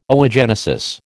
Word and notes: oogenesis: American English

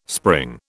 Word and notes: spring: British English

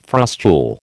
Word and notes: frustule: American English

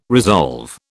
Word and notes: resolve: British English

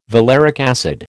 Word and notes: valeric acid: American English

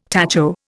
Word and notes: tacho: Indian

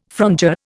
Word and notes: frondeur: Indian

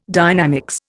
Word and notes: dynamics: Indian

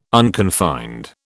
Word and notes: unconfined: British English

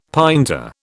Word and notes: pinta: British English

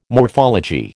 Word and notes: morphology: American English

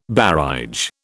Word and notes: barège: British English

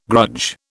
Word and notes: grudge: British English

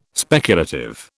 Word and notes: speculative: British English

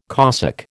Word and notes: Cossack: American English

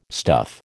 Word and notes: stuff: American English

